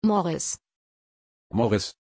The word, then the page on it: Morris: German